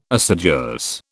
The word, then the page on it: assiduous: British English